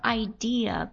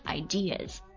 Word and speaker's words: idea: 要重点讲一下 idea, 观念想法这个单词，不要读成 idea, 好不好？我们说大多数情况下，一个单词里面有字母 R, 才会有 R 这个发音。如果没有的话，那就只能是 So, 这个单词念作 ideas.